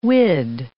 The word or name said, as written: whid